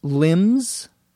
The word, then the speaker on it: limb: Elena says, you mean people who have lost limbs? A limb, L-I-M-B, the B is silent, we don't pronounce it, is either an arm or a leg.